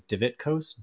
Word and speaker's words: Divitkos: My last name is Divitkos, DIVITKOS.